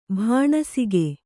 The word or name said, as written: ಬಾಣಸಿಗ